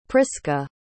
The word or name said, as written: prisca